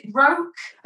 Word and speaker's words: Roque: And I think Idris Elba, so Rock, was it Roke? – I think it's Roke. Yeah, they're all known by their surnames. I think it's Roke, but it's spelt like Roque, so R-O-Q-U-E, I think.